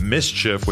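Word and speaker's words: MSCHF: The sneaker collaboration with Mischief, which I now realize that's how it's said, contained a Nike Air Max 97 with satanic imagery and a single drop of human blood in the midsole.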